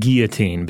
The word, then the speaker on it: guillotine: It's got to be the And Robert, before I say it, do you say it like a French guy's name or like what a fish breathes with? – I go with guillotine because it sounds a little more like an open-faced sandwich that way.